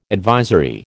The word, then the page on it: advisory: American English